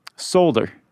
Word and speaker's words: solder: Did you say soldier? – Solder. – Solder? – Yeah. – Never heard it pronounced that way. – How do you say it? – Solder. – A solder. You got to say that L. – I think the L silent, unless it's regional.